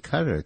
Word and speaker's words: Qatar: The country of Turkey and the country of Qatar, Q-A-T-A-R, pronounced Qatar.